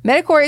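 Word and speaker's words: MedicCorps: So it's Medic, C-O-R-P-S, which is Core, right? That's how you pronounce that? So they started this company. Medicore is a Christian U.S.-based non-governmental organization that deploys our cross...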